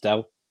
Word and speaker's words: Del: And my name's not actually Delwyn. It's just Del. Three letters, one syllable, not short for anything.